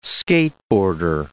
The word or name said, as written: skateboarder